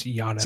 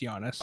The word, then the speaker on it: Giannis: It's Giannis. – That's it. I mean, that's all I got to say. – Soft G. You don't pronounce the G in his name. It's Giannis. – Oh, I'm sorry. Giannis.